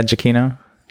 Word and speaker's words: Giacchino: I think so, yeah, Giacchino.